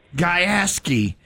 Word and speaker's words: Gajewski: Gajewski.